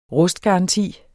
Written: rustgaranti

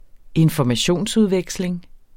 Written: informationsudveksling

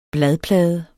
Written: bladplade

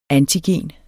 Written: antigen

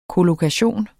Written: kollokation

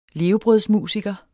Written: levebrødsmusiker

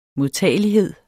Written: modtagelighed

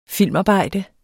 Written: filmarbejde

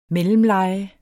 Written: mellemleje

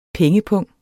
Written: PENGEPUNGE

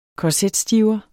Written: korsetstiver

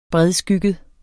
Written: bredskygget

